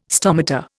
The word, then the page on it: stomata: Indian